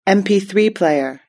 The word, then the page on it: mp3 player: British English